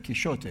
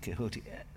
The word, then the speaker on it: Quixote: But actually, the strange thing about the X is that in Spanish, that X, in the time of Cervantes, that X would have been pronounced like a sh. – Yeah. Yeah. – So in his, they would of course have pronounced the E. So he would, Cervantes would probably have said Quixote. Yeah. You know, the X turning into a h sound is much later.